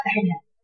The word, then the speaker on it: مجريها: هتبقى مرققه ولا مفخمه؟ مرققه يبقى تقولي هنا فيها اماله والراء مرققه وكيف نقراها؟ – مجريها عرفتي البنت اللي بتسال دي؟